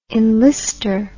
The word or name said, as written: enlister